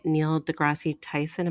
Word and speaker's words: Neil deGrasse Tyson: a Neil deGrasse Tyson.